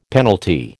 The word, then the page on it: penalty: American English